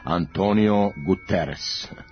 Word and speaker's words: Antonio Guterres: novi generalni sekretar Ujedinjenih nacija na srpskom jeziku se izgovara i piše Antonio Guterres.